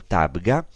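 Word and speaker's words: Tabgha: Tabga, es el nombre mal pronunciado del término griego heptapegon quiere decir siete fuentes.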